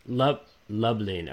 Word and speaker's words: Lublin: I can't imagine you guys have no shortage of beautiful bookstores, because I was googling how do you say your city? Lublin, is that correct? – Lublin.